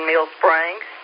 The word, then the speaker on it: Mill Springs: 3JO1, the Blue Ridge 2-meter net, would like to say good evening to W4CWF colon in Mills colon. Gary tells me I'm mispronouncing it. Colon, I apologize. In Mill Springs.